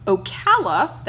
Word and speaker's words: Ocala: Ocala. That is O-C-A-L-A.